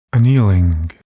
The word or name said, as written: annealing